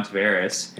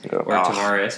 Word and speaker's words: Tavares: And then the second coolest interview I did is I interviewed John Tavares, Tavares, however you want to pronounce it. – Now that he's in Toronto, it's the Portuguese Tavares. – Yeah, so... At the time, he told me that he didn't care, but it was more Tavares.